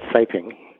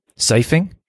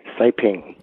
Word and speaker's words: siping: That's called siping. – Siphing? – Siping. – Oh, siping. Okay, so they should have more siping in each tread, or is mm-hmm.